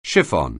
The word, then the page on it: chiffon: anglais